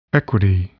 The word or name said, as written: equity